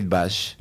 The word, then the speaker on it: Kate Bush: Vamos a pasar ahora a una música de Kate Bush o Kate Bush.